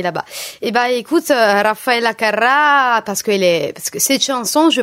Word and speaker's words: Carrà: Carrà, Raffaele Carrà. – Carrà. Carrà. Io faccio la R moche. À la Torinese, là où tu es toi d'ailleurs.